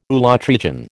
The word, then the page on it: ulotrichan: American English